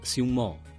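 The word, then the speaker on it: сюмо (熊猫): По-китайски слова «панда» произносится как «сюмо», что означает «медведь-кошка».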